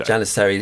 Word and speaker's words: Janissary: So how did you just pronounce that? Because that's where I what Janissary. I, I, said Janissary because I've heard uh, Craigie Zildjian um, pronounce it that way. Who's obviously the daughter of Armand, and um, I've heard Janissary. What did you say? Janissary is how I would say it.